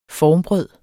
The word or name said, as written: formbrød